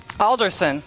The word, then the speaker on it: Alderson: I have somebody I just met, Miss Willie Anderson. – Alderson. – Alderson. There you go. I had it written wrong.